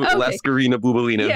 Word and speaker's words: Laskarina Bouboulina: So let's get back to Laskarina Bouboulina, who I think I said it correctly this time. – You did. Yes. Woo. Gold star. Woo.